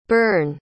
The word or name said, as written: byrne